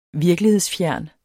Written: VIRKELIGHEDSFJERN

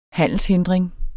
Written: handelshindring

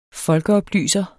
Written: folkeoplyser

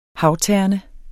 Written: havterne

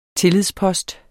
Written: tillidspost

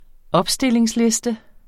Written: opstillingsliste

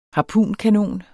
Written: harpunkanon

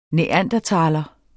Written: homo

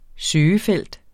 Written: søgefelt

